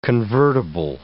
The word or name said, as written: convertible